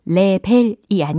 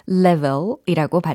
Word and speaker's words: level: L-E V-E-L 이라는 철자죠. 레벨이 아닌 level이라고 발음을 해주시고요.